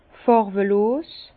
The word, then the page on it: fàrwelos: Bas-Rhin; Herrlisheim